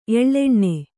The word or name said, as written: ಎಳ್ಳೆಣ್ಣೆ